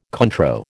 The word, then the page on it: Cointreau: American English